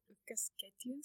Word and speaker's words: Ykkösketjun: Before we do that, can you explain what Ykkösketjun stands for? I hope I pronounced yes. – it well. – yes, you did. Well, Ykkösketjun, which is you know, the name that they chose for this campaign, it means to the first line.